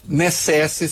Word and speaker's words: necesse: necesse se escreve com C, mas se lê K, muito provavelmente.